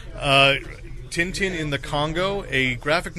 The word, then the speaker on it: Tintin: They kept pronouncing it. 10-10. I was like, where do you get 10-10 out of that?